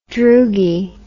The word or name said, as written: droogie